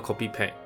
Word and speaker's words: コピペ: 原文的标题叫做 Yamaha no Copy Pad。 Copy Pad 这个字跟大家介绍一下，它是用片假名写成 Copy Pad。那这个字其实来自于也是日本，应该说它是一个日本乡民的网络用语，就是 Copy and Paste 复制贴上的外来语，再经过简略而成的，就直接念作 Copy Pad，Copy and Paste。所以 Yamaha no Copy Pad。